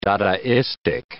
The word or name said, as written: dadaistic